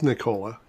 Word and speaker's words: Nikola: so, so uh, Nikola, it is Nikola. It is Nikola.